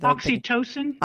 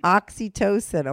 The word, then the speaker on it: oxytocin: Oxytocin. – Oxytocin. Oh, my God. I'm so fucking dumb. I said it wrong 10 times.